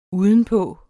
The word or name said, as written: udenpå